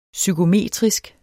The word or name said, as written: psykometrisk